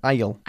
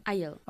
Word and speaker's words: aisle: Yeah, that's difficult to pronounce, isn't it? Aisle. – Aisle. – Aisle. – Aisle. – The pronunciation has nothing to do with the way it's written.